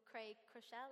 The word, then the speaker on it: Craig Groeschel: And we um, had this most amazing time, and there was this amazing speaker called Craig Groeschel. Am I saying that right? Yeah.